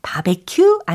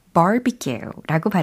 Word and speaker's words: barbecue: 예, 바비큐, 바베큐 아니고 barbecue라고 발음하시면 되겠어요.